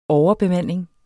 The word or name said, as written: overbemanding